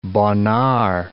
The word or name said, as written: Bonnard